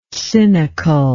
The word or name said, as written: cynicalness